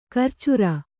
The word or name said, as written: ಕರ್ಚೂರ